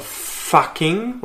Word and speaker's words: fucking: You'll notice there when you go back over it that I really, really emphasize the, the first syllable of fucking.